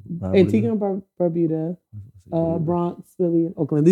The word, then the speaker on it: Antigua: First of all, it's Antigua. – Antigua. – Yes. – It's Antigua. – Most people don't know how to say it. So, but I'm here to correct it.